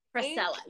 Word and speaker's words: Frisella: It's Frisella. – Frisella, yeah.